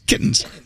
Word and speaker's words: kittens: Kittens. – You gotta get the T in there. Kittens.